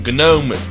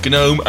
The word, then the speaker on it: GNOME: And in this podcast, we are talking Gnome, Gnome and more Gnome, with the G pronounced every single time.